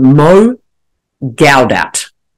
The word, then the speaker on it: Mo Gawdat: And one of those people is a man called Mo Gawdat. I'm just going to see if I can say his name properly. M-O, surname G-A-W-D-A-T.